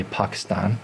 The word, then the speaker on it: Pakistan: And we didn't make it to Pakistan until, or if you want to say it properly, Pakistan.